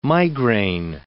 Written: migraine

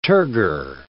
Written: turgor

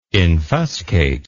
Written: infuscate

